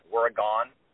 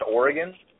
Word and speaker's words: Oregon: And all I remember is the boyfriend mispronounces Oregon. He calls it Oregon. And then Crisp repeats that. He goes, Oregon. And I was thinking, nobody in the effing film crew knew that it was pronounced Oregon anyway.